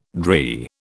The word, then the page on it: drey: British English